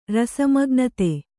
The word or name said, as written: ರಸಮಗ್ನತೆ